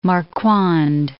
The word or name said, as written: Marquand